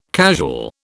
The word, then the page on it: casual: British English